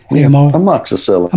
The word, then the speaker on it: amoxicillin: Oh, he had a week's course of amoxicillin. Amox? How do you say it? – Amoxicillin.